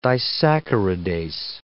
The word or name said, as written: disaccharidase